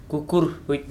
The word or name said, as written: kukurhwi